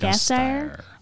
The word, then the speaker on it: Gasteyer: Gasgeier? How do you say her last name? – Anna Gasteyer? – Gasteyer.